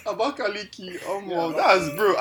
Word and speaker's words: Abakaliki: Other than the capital is Abakaliki, like.